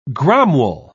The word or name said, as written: gromwell